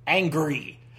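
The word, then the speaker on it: Angourie: It looks like Anguri, Angry, Angry Rice. Oh no. She was in the Walking with Dinosaurs movie from 2013. Oh, honey, no. No, no, no, no, no. Uh, pronunciation. How do you pronounce? Here, how do you pronounce that name? Uh, how to pronounce Angori? Uh, pronunciation by somebody in Australia. It is angry.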